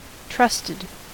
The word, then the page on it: trusted: American English